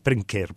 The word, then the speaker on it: Brynkir: it's Brynkir, but B-R-Y-N-K-I-R.